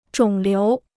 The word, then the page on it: 肿瘤: zhǒng liú